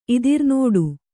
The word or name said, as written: ಇದಿರ್ನೋಡು